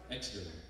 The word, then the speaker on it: Exeter: Well, it's great to be here in Exeter. How do you say that? – Exeter.